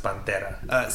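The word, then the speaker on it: Panera: First of all, it's pronounced Pantera.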